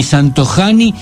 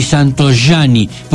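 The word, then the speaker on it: Santojanni: Se escribe con J, pero no digan ni Santojani ni Santoiani, porque en italiano la J suena ahí. Entonces se dice Hospital Santoiani.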